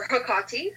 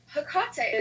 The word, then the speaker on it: Hecate: And so I moved on to, I now work with Hecate, or Hecate, however any of your listeners want to pronounce it. – Oh, it that's very, a Greek goddess of the hearth, correct? – Hecate is the Greek goddess of pathways and gateways.